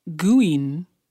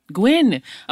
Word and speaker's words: Guinn: I also found one from Jane Guinn. That's a G-U-I-N-N. Did I say it right? I don't know. She can't tell me. Gwen.